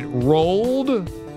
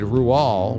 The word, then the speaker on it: Roald: 12.5% said Rual, which apparently is the correct pronunciation for Rual Dahl.